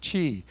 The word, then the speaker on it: qi: It's called qi. How many have heard of qi? It's spelled funny, Q-I, qi.